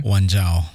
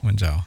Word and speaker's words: Wanjau: Wanjau. – Wanjau. – Wanjau.